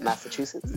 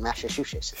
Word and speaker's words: Massachusetts: Massachusetts. Did I say it right? Massachusetts? – Yeah, you said it right. – All right, cool, cool. Man, I used to struggle. It would be like Massachusetts.